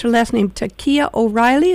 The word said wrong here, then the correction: Takla O'Reilly: is that correct? – Takla O'Reilly. – Takla O'Reilly.